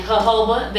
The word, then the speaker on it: jojoba: So because, you know, I'm on my natural tip, I use coconut oil and jojoba. That's J-O-J-O-B-A, in case you didn't know how to pronounce that.